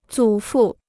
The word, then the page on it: 祖父: zǔ fù